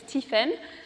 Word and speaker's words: Tiphaine: My name is Tiphaine Rosario, or for those who can pronounce French, it's Tiphaine.